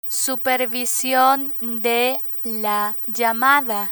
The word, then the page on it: supervision de la llamada: Spanish